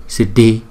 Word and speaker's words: Siddhi: Chữ D và chữ H chúng ta đọc là D, D, Siddhi, Siddhi, Siddhi, Hum Siddhi.